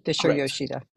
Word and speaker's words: Fisher-Yoshida: Fisher-Yoshida.